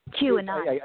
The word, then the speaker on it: QAnon: I think it's pronounced Quanin. Am I pronouncing it correctly? – Q. Well, I mean, QAnon. – I guess, yeah, QAnon as in like anonymous, because the whole thing started in the 4chan and then 8chan comms.